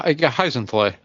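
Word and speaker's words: Hyzenthlay: So, Hannah Gordon, who played Hizenthlay. Did I say that right? Hizenthlay? God, these names, man. – Right? – Yeah, Hizenthlay.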